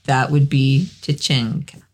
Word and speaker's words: tacenda: It's tachenda. Tachenda. Tachenda. Spelled T-A-C-E-N-D-A. Tachenda. It looks like tachenda, but it's pronounced tachenda.